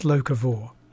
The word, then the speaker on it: locavore: And is it pronounced locavore? – It is pronounced locavore.